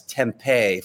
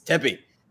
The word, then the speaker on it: Tempe: Can we just say this for all the, I don't know if we have any out-of-town listeners, but you might have heard the pronunciation as Tempe from certain people. – What? – That's not it. That's not really? It. – Tempe. Seriously? – Tempe. Yes, I'm not going to name names, but Tempe is not correct. It's Tempe. Tempe, all right? Get that right.